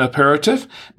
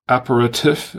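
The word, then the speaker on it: apéritif: By the way, there are a couple of ways to pronounce that. Aperitif or aperitif. I've heard it both ways. Aperitif, or as the French say, aperitif. That's how they pronounce it.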